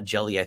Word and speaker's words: GELI: And I thought we'll upgrade Jay's systems, and so uh, it turns out that that as we said, the GELI uh, jelly, I think is how it's pronounced, the encryption keys that were in the old version, it dates back to FreeNAS, are BSD only.